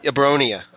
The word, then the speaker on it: Abronia: Abroni? Is that how you say it? – Abronia.